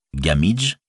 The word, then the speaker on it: Gamwich: Gamwich, prononcé Gamidge, semblait donc un bon équivalent.